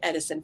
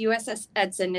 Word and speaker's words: Edson: we went to film at USS Edson, and to make sure I say that right, because I keep calling it Edison for whatever reason. But it's USS Edson.